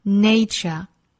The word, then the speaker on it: nature: 那这句话呢，英音和美音并没有什么太大的发音上的差别啊。我们放在一起来讲语音的部分。首先我们来看前半句 We have to give Mother Nature, 这里的 nature, 我们注意发音双元音 A,Nay, 后面呢是 ch, nature, nature. 要注意 nature 和形容词 natural 的发音区别。Nature, natural, natural, natural. 都同样是字母 A, 但是名词的大自然读成 nay, nature. 而形容词自然的读成 natural.